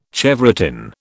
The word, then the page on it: chevrotain: British English